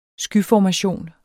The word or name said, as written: skyformation